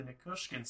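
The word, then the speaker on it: Nichushkin: And the first thing is actually going to be the Nikushkin signing in Colorado. And oh, nice pronunciation, man.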